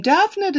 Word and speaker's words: Damhnait: Davnet is spelled D-A-M-H-N-A-I-T. And that breaks down to Dav and Net. Dav is actually Gaelic for fawn. And then Net, I think, is the diminutive of the word.